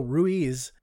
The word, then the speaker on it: Ruiz: it's spelled R-U-I-Z, which traditionally is Ruiz, but anytime I've heard his name said on television, they say Ruiz.